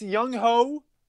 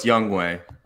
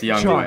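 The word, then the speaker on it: Younghoe: Wait, his name isn't pronounced Young Ho? – No, it's Youngway. – Oh, boo. – Yeah, it's Young. It's Youngway.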